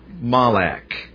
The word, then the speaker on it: malak: First of all, under the word angel, the Hebrew word in the Old Testament is the word malak. And I expect the best way to spell it in English would be M-A-L-A-K, malak.